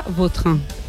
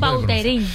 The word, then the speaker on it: Vautrin: La original francesa, Vautrin. – Ahí pega un Vautrin.